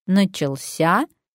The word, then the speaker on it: начался: И яркий пример – удался или удался, начался или начался. Логично думать, что здесь будет действовать правило аналогии, но нет. Начался ударяется на последний слог, как и положено всем глаголам.